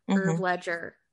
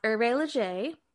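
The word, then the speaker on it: Hervé Léger: which some people will pronounce Herve Ledger. It's not pronounced Herve Ledger. It's pronounced Herve Leger, or you could just call it HL.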